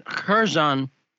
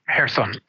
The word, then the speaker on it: Kherson: Yeah, Kherson. Mm-hmm. They the Kherson. Yeah, thanks for correcting me, because I, I I suck at pronunciation. But the uh, I pronounce it like an American.